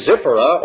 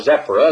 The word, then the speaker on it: Zipporah: And Zipporah, or Zephora, as they pronounce it,